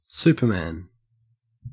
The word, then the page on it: superman: Australian English